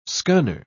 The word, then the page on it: scunner: British English